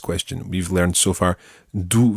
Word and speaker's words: d'où: Okay, so we've got d'où viens-tu and d'où venez-vous. The first word in both these sentences is the same. It's d'où, d apostrophe O U with an accent on the U.